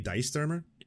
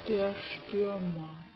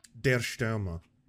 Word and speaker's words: Der Stürmer: How to pronounce Der Stürmer. Is it going to be Die Stürmer? – Der Stürmer. – Der Stürmer. That wasn't that far.